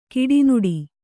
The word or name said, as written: ಕಡುನುಡಿ